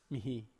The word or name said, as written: mihi